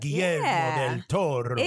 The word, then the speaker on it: Guillermo del Toro: Guillermo del Toro. – There you go. – Like that? Guillermo del Toro.